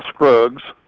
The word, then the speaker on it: Scruggs: My name is Phil Scruggs. My last name is spelled S-C-R-U-G-G-S.